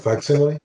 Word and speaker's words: facsimile: how do you say it again? – Facsimile? – Yes, facsimile. That's always been a word I've had trouble with.